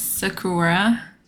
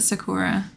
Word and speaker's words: Sakura: Yes, Sakura. That's how I would pronounce it, Sakura. – Is it like the Japanese one? Like the Sakura? Yeah, like the cherry blossom.